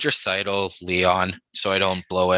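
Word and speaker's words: Draisaitl: How do you pronounce Dreisaitl though? – Exactly the way you just said it. – Oh, Dreisaitl.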